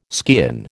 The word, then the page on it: skean: American English